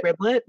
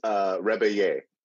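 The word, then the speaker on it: Rebillet: I always want to call him Mark Riblet, but that's not his last name. – McRiblet, it's back, guys. No, it's Rebeye.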